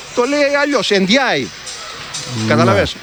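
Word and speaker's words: Ndiaye: Εντζάι, ναι. Τελικά έτσι προφέρεται το όνομά του, γιατί μας έχουν μπερδέψει και οι αντίστοιχοι ποδοσφαιριστές που έχουν το ίδιο επώνυμο.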